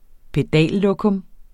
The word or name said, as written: pedallokum